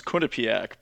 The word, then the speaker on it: Quinnipiac: But the actual pronunciation, well, the school's pronounced Quinnipiac, but then like it's named after a Native American tribe, which is Quinnipiac. That tribe, so no one pronounces it actually correctly.